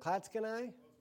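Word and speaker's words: Clatskanie: Then I drove to Klatskanai, is that how you pronounce it, Klatskanai? Yeah, close enough, Oregon.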